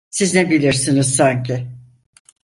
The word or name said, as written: sanki